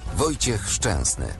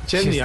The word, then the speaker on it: Szczęsny: Acá le decimos Chesney. – Sí, Chesney. – Vea cómo se pronuncia. – Wojciech Szczęsny. – Szczęsny,